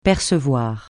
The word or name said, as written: percevoir